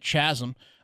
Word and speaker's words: chasm: due to the giant chasm, and check in on how the Kenyans are doing. – Chasm. – Chasm. – Chasm. I think chasm. It's a silent H on that one.